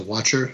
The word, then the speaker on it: Wachter: The artist is Dave Watcher, W-A-C-H-T-E-R. So Watcher, I think, is how it's pronounced.